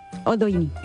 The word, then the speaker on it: Oldoini: Virginia Oldioni, la condesa Oldoina. De... ¿Oldioni? ¿Lo he dicho bien? – Oldoini. – Ah, perdona. No, está bien que me corrijas.